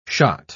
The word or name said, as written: shot